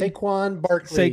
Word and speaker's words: Saquon Barkley: Quan Barkley? – Saquon Barkley.